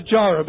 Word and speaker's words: Jireh: Jehovah, I think this is pronounced Yireh. We tend to sing Jehovah Jireh because of that chorus that we sang years ago.